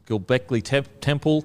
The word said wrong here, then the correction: Göbekli Tepe: can't even say it properly. – Göbekli Tepe. – Tepe.